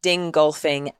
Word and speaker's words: Dingolfing: Dingolfing and Munich plants. Did I say that right? – Yes, you did. – Okay. In the Dingolfing and Munich plants, BMW relies on regional green electricity from hydropower.